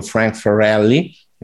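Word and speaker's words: Frank Farrelly: Frank Ferrelli che è la pronuncia giusta, lui si incazza se lo pronuncia sbagliato,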